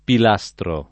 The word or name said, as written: pilastro